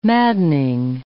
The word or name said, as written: maddening